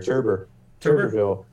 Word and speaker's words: Tuberville: But that's, is that how you pronounce it? It's just like Coach Tuberville? – Turber, yeah, a little bit of an R in there. Tuberville.